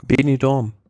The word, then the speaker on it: Benidorm: Bing, ich komm, Benidorm.